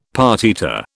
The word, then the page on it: partita: British English